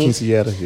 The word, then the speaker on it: quinceañera: So we did a quinceañera. Is that how you say it? – Yeah, quinceañera. Yeah.